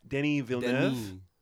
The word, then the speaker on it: Denis Villeneuve: So Sicario, the first one, was directed by Denis Petty. How do you pronounce that? – Denis Villeneuve.